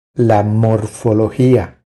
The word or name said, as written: La morfologia